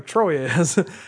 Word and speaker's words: Troyes: I think it's pronounced Troyes. Or as we say in Indiana, Troyes.